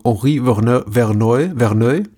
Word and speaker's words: Henri Verneuil: Aber eben zuerst Angst über der Stadt von Henri Verneuil, denke ich mal. – Würde ich aussprechen, ja, genau.